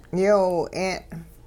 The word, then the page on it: yoowi-'e'n: Hupa